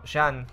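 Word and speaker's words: Jean: Eh, Jin le dice a Reiner que eh, los de la región no se. legión no se rinden. Jin No, Jan, como según se pronuncia. Jan, claro.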